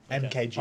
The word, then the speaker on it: Mk.gee: MK.GEE. – Oh, right. I thought it was just MK.G. – That's what I thought you said too. – G E, but it's pronounced MKG.